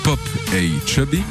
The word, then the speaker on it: Popa Chubby: et que l'on prononcera à l'anglaise Pop A Chubby, signifie avoir une érection.